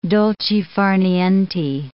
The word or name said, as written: dolce far niente